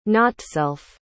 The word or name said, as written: notself